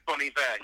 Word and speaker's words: Bon Iver: How do you say it? – Well, it is Bon Iver. He's a French Canadian, and Bon Iver means good winter.